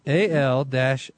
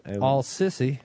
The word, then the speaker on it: Sisi: Do you think he pronounces it that way? – I don't think so. – Al-Sisi. That's what it is. Al-Sissi. How would you pronounce that? – You got it. You nailed it.